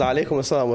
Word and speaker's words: alaikum salam: But the way she pronounced alaikum salam was absolutely correct.